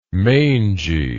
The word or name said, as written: mangey